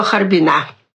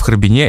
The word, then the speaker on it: Харбин: Ну, а потом, значит, дальше станция Маньчжурия, и, в общем, уже добрались до Харбина. – Кстати, вот это ударение на последний слог «Харбина» в «Харбине» — это типично для наших героев, они все так говорят.